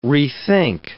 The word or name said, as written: rethink